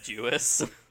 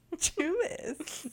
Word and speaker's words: Jewess: Jewess. – Jewess. – You're putting too much emphasis on the S.